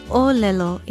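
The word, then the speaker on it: olelo (ʻōlelo): Aloha, I'm Lilani Poliahu of Hawaii Public Radio, and our Hawaiian word of the day is olelo. It begins with a glottal stop, or okina, and the first O is stressed. It is spelled okina, O-L-E-L-O, and it means language, speech, statement, utterance, term, tidings, or to speak, tell, say, talk.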